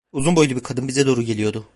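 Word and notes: bize: Turkish

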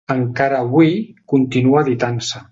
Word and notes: editant: catalan

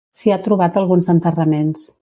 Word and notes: trobat: Catalan

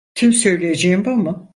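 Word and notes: mu: Turkish

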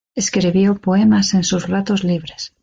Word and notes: libres: spaans

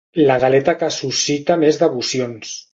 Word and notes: devocions: catalan

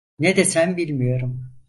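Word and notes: bilmiyorum: Turkish

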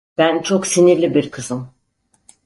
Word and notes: sinirli: turc

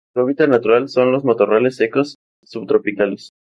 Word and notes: matorrales: Spanish

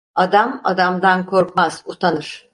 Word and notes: adamdan: turkish

